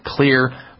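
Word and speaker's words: Xlear: If it's organisms that we need to clear out up there, and clear, by the way, is spelled with an X, X-L-E-A-R, the X stands for xylitol, one of the components, because their rescue formula has all those antimicrobials in it that help kill off the fungus, the yeast, and the mold.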